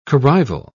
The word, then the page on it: corrival: British English